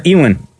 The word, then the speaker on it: Ewan: Ewan or Ewan? – Ewan, man.